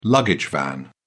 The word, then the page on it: luggage van: British English